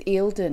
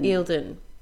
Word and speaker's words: Eildon: Is that how you say it? – I think it's Eildon. – Eildon.